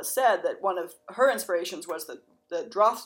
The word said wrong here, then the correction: Draughtsman's: I'm gonna say this wrong. – The Draughtsman's Contract. Thank you, which we have reviewed on the blog.